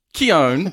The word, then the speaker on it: Keoghan: Warner Brothers has released a deleted scene of Barry Keoghan's... – Keown. – Okay. I had Barry Keoghan. That's the pronunciation I had in my head. And I buggered it up again. Barry Keoghan. – Yeah. – It's Barry Keoghan.